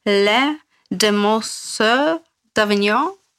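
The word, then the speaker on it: Les Demoiselles d'Avignon: Sein bekanntes Werk war, wie wird das auf Französisch ausgesprochen? Le Demoiselles d'Avignon. – Das hört sich ja. sehr gut an.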